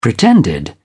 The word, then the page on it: pretended: British English